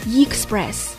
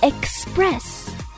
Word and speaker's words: express: 首先，因为它是一个 e 打头的单词，所以我们一定要注意前面不要再加一个 y，不要把它讲成 express，争取把它读成 express。